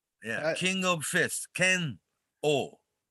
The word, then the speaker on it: Ken-O: Ken-O, people in English-speaking world, you know, it's hard to pronounce. They always say Ken-O, right? – Mm-hmm. – It's Ken-O.